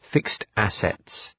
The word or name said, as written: fixed-assets